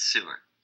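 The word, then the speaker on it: Seewer: Sewer, I think. – It's Sewer. Listen. – We're going to pronounce, Sewer. Listen, we're going to, this is American podcast. We're going to announce this shit American.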